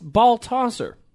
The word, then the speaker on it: Baldasser: Baldasser. That's how it's spelt. But it's pronounced Baltasser.